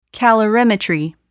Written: calorimetry

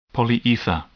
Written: polyether

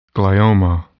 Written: glioma

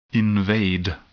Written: invade

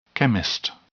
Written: chemist

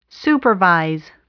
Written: supervise

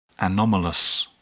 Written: anomalous

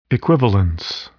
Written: equivalence

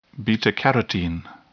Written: beta carotene

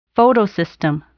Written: photosystem

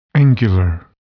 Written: angular

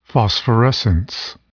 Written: phosphorescence

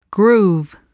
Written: groove

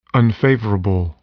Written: unfavorable

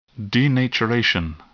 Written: denaturation

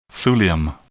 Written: thulium

